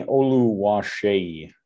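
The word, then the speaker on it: Oluwaseyi: Tani Oluwase, I think that's the actual pronunciation. Yeah. Good job. – Tani, I just remember the Shay at the end. Shay.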